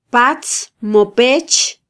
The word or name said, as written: patz mo pech